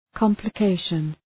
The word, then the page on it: complication: ,kɒmplə’keıʃən